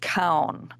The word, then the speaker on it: Keown: I, I believe the last name is pronounced Kown, K E O W N.